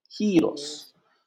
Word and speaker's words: giros: Y jeros, jeros, jeros. – Giros.